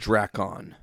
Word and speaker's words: Drakkon: It's Drakkon. – I would say Lord Drakon.